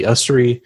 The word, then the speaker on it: Ussery: You'll see my name, Andy Ustry, on there. I'm on Instagram. Just look my name up, Andy Ustry.